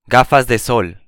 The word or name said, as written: gafas de sol